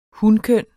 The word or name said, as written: hunkønnet